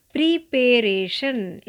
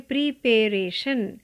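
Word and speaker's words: preparation: प्रीपेरेशन इसको लोग पढ़ते हैं प्रीपेरेशन यानी तैयारी आपको इसे पढ़ना है प्रेपरेशन प्रेपरेशन इसका सही प्रोनाउंसिएशन प्रेपरेशन ना कि प्रीपरेशन ना ही प्रीपैरेशन इसे कहेंगे प्रेपरेशन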